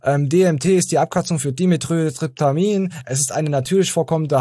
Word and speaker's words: Dimethyltryptamin: Dimethyltryptamin. – Dimethyltryptamin, da habt ihr es gehört.